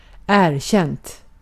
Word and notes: erkänt: /ˈɛːrˌɕɛnt/; /ˈeːrˌɕɛnt/

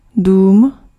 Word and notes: maison: /mɛ.zɔ̃/

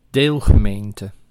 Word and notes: deelgemeente: [ˈdel.ɣə.men.tə]

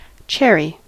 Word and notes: cherry: [ˈʧɛ.ɹi]; /ˈt͡ʃɛɹi/

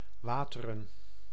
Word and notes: vatten: [ˈvat.ɛn]; /ˈvatː.ɛn/

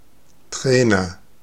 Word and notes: coach: [koʊtʃ]; /kəʊtʃ/; [kʰəʊtʃ]; [kʰoʊtʃ]

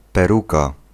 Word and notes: wig: [wɪg]; American English

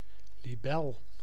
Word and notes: libellule: France; [yn li.be.lyl]; /li.be.lyl/; /li.bɛ.lyl/

